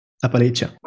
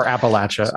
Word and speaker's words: Appalachia: Appalachia. – Or Appalachia, I guess, depending on where you're from.